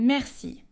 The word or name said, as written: merci